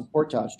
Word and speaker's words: portage: I used to watch guys who were 85 years old haul a canoe across a portage trail. Yes, I said portage because I'm Canadian. That's how you say it.